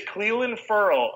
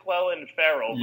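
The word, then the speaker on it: Clelin Ferrell: Yeah, it is Clelin Furl. I know it looks like Clelin Farrell, but yeah.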